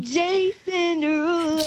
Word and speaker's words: Jason Derulo: How do you say Jason Derulo's name? – Oh my God, no. – Come on, do it. – No. – Come on. – Jason Derulo.